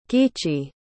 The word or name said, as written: giichi